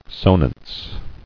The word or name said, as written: Sonance